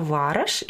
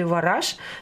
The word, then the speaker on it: Вараш: И мы посетили там города, один город называется Вараш или Вараш, то есть сами жители употребляют и то, и другое произношение, как бы ударение используют.